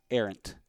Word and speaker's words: Errant: It's pronounced Errant. It's pronounced Arian? – Like Errant. It's like heir to the like heir to the empire. – Okay. – Heir and then just ant. Okay. A-N-T.